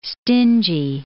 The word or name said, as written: stingy